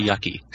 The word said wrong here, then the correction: Yuki: I thought it was Yuki. – I'm not sure how to pronounce it. – I'm pretty sure it's Yuki because Yucky sounds bad.